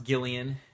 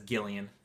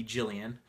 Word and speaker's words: Gillian: So I put a couple of those on my list, and today I listened to The Grown Up by Gillian Flynn, and it's pronounced Gillian on the audiobook. It says Gillian a few times, so I thought it might be Jillian, but it's Gillian.